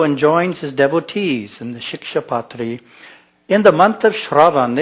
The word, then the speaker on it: Shikshapatri: In the Shikshapatri, Bhagwan Swaminarayan states, practice that word for a day, rhymes with Diksha.